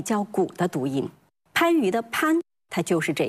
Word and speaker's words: 番禺: 那么类似的例子，比如广东有一个地名叫番禺，它的这个番字在正常情况下应该读番，可是就在这个地名里面保留了远古古无清纯音时代的发音，它读番。